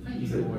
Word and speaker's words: Feige: ¿Cómo se pronuncia? Feige.